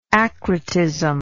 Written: acrotism

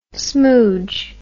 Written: smouge

